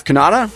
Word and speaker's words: Cannata: I am your host, Jeff Cannata.